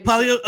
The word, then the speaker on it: Palio: The Palio di Siena. – Yeah, okay. – The race. – Yeah. The Palio. – Yeah, Palio. Yes, Palio. Okay.